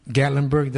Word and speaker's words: Gatlinburg: They had a drive to Gatlinburg.